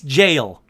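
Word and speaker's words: gaol: How the hell do you pronounce gale? Is it jail? Sure enough, it's jail. It's just jail.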